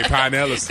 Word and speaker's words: Pinellas: You got to say Pinellas.